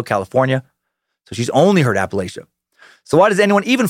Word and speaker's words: Appalachia: on how to pronounce Appalachia, and 76% said that Appalachia is the way you say it.